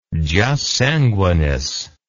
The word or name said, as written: jus sanguinis